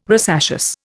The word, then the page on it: rosaceous: Indian